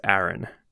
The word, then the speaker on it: Aran: I think it's actually Aran, though, because I think Aran's probably right. – Yeah, because there's like maybe two lines of dialogue that's actually recorded in Metroid Prime at the very start. And I'm pretty sure they say her name is Aran.